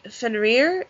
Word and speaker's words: Fenrir: There's also Fenrir. Is that how you say his name? – Yes.